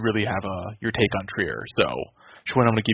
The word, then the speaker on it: Trier: We had a lot of Alonzo Trier, or Trier, as our boy Macri likes to point out as the proper pronunciation.